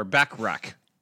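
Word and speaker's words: Bachrach: Eben Moss Bachran, or Backrack. Yes, that's how you say it.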